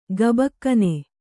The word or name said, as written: ಕಬ್ಬಗಣ್